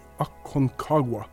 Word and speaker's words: Aconcagua: Ak Aconcagua.